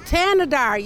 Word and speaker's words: Thanedar: Shri Thanedar, right? Tanadar, yes, yeah, yes, yeah.